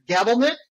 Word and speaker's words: Gabelnick: Gabelnick? – Perfect.